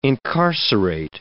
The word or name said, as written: incarcerate